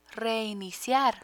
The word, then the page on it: reiniciar: Spanish